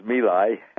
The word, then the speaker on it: My Lai: Actually, it's My Lai, but that's the way they pronounce it there. And everybody pronounces it My Lai.